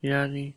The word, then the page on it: lyali: Lwaitel